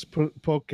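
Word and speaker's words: poké: It's Poke, It's poke. It is pronounced poke.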